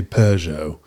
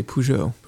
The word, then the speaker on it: Peugeot: In England, they say Peugeot. – Yes. – There's an R in there. – What do we say? We say Peugeot. – Peugeot. They say, yeah, England puts the emphasis on the first syllable, Peugeot, and we call it Peugeot.